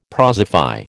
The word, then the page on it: prosify: American English